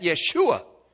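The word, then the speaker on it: Yeshua (ישוע): In the Hebrew, they pronounce that Yeshua.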